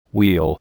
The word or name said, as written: wheel